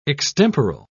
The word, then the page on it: extemporal: British English